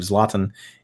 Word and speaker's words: Zlatan: been trying to do better with I believe Zlatan is is the better pronunciation.